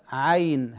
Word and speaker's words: عين: من الأخطاء الشائعة في نطق هذه الكلمة أن بعض الناس يكسرون العين أو لا يوضحون الفتحة، يقولون عين عين صاد، لا عين هكذا عين، كما تنطق بالعين في كلمة عين، هذه تسمى العين، عين عين هكذا نطقها، لكن مع مد الياء عين.